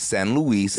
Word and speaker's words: San Luis: San Luis, Arizona comes in at 7. – Actually, you're saying that wrong. It's San Luis, and that's on the border.